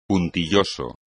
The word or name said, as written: puntilloso